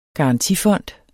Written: garantifond

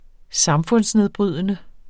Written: samfundsnedbrydende

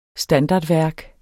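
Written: STANDARDVÆRK

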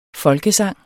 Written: folkesang

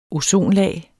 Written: ozonlag

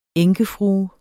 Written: enkefrue

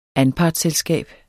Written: anpartsselskab

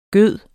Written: gyde

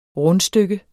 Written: RUNDSTYKKE

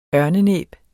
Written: ørnenæb

